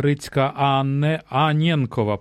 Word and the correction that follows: Аненкова: перепрошую, якщо неправильно... – Аненкова, так, неправильно наголос зробив.